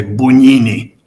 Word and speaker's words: Bugnini: Esta palabra, este nombre es muy importante, Bugnini. Se escribe B-U-G-I-N-I, Bugnini.